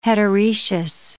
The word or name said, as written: heteroecious